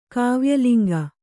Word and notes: ಕಾವ್ಯಲಿಂಗ: Kannada

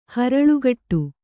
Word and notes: ಹರಳುಗಟ್ಟು: haraḷugaṭṭu

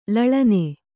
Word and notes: ಲಾಲನೆ: lālane

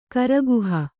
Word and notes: ಕರಗಹಿ: karagahi

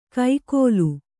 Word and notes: ಕೈಕೋಲು: kai kōlu

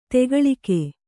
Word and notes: ದೊಗ್ಗಾಲಿಕ್ಕು: doggālikku

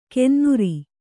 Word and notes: ಕೆನ್ನುರಿ: kennuri

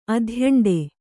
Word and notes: ಅಧ್ಯಂಡೆ: adhyaṇḍe; Kannada

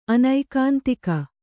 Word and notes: ಅನೈಕಾಂತಿಕ: anaikāntika

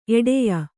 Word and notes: ಎಡೆಯ: Kannada

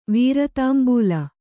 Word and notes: ವೀರದಂಬುಲ: vīradambula

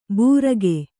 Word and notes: ಬೂರಗೆ: kannada

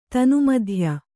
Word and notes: ತನುಮಧ್ಯೆ: tanu madhye

